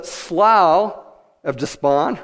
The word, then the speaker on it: Slough of Despond: And he's running to the slough of despond, right? Because that's the way it's pronounced.